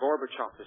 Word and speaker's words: Gorbachev: Am I right in pronouncing his name? – Gorbachev.